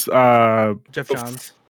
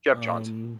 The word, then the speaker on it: Geoff Johns: Was it Geoff Jones? – It's not. I don't think it was. – No, Geoff Jones was. – Jeff Johns. – Yeah, I always pronounce it wrong, but Geoff Johns.